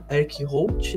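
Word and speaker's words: Eric Roth: Vai da, para quem, pra quem acompanha futebol, é igual o, o Celso Roth. Né? O Roth se pronuncia, é, se escreve da mesma forma. Então é o Eric Holt, né?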